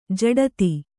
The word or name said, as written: ಝಡ್ತಿ